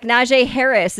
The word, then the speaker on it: Najee Harris: NFL running back Najee Harris.